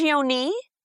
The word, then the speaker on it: Mangione: Is it Mangione? Am I saying that right? – It's Mangione. – Mangione? Okay. I want to say it right.